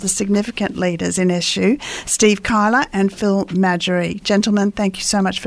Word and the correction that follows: McGarry: Steve, Phil, did I say your name right? Madgery? – McGarry. – McGarry. Yeah. I'm so glad I checked that straight up. Otherwise, I'd be saying that all the way.